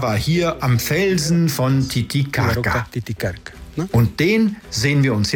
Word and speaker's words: Titicaca: Richtig ausgesprochen, Titicaca. Dieser heilige Ort der Inkas gab dem ganzen See seinen Namen. Die spanischen Eroberer konnten es aber nicht richtig aussprechen und so wurde aus Titicaca Titicaca. Aber korrekt müsste man sagen Titicaca.